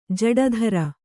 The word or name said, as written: ಜೋಡಿದಾರ